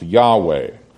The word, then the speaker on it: Yahweh: That indicates it's the divine name, usually pronounced Yahweh.